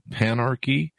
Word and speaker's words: panarchy: Collective flow, panarchy, if I'm pronouncing that correctly, simultaneity, and consilience, if I'm pronouncing all those correctly. – Yes.